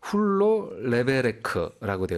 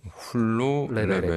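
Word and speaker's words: Hulló levelek: 이게 어떤 발음인가 한번 찾아보니까요. – 네네네. – 훌로 레베레크라고 되어 있더라고요. – 훌로 레베레크.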